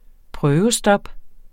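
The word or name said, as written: prøvestop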